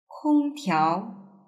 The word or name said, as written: kongtiao